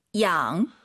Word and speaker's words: 养: 양. – Y가 있으면은요. 마음속으로 I로 바꾸세요. 이게 한글로 많은 분들이 양 이렇게 읽으시는데 정확한 발음은 이 양이에요. 그죠? – 네, 양. – 네, 이로 시작해야 돼요. 양이 아니에요. 양.